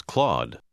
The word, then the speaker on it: Claude: Pronunci l'ultima parola come se non ci fosse una E finale. – My cat's name is Claude.